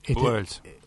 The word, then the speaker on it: Goebbels: Usted sabe que han vendido, pasa que siguen mucho, la izquierda ha logrado que Joseph Beger, como siempre lo pronuncio mal, el alemán no entiendo un pito, el famoso publicista que tuvo Hitler. – Goebbels. – ¿Cómo? – Joseph Goebbels.